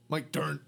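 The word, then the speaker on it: Mike Dirnt: Mike Durnt. Durnt, durnt, durnt. – His last name sounds like hitting a bass note.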